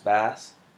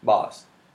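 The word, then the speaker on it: Bas: Is it pronounced Bass? It's Tribe. – Boss.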